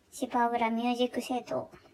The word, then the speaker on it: 芝浦音楽倉庫: えー、芝浦音楽倉庫と書いて、芝浦ミュージックシェイトというラジオです。